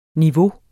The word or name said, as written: niveau